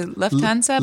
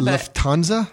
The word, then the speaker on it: Lufthansa: I think some people say Lufthansa. – Lufthansa? – I think that's right. But I say Lufthansa because that's how they say it in Spain.